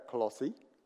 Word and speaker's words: Colossae: Okay, so there's Colossae, I went on Google, and that's how you say it, Colossae.